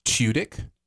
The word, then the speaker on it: Tudyk: Alan Tudyk is in the show. – Oh, nice. – Yeah, it's just a great, great, huge cast. – Who I recently learned pronounces his name Tudyk.